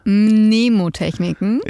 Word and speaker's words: Mnemotechniken: Mnemotechniken. Wie spricht man es richtig aus? Mnemotechniken. – Ja, okay.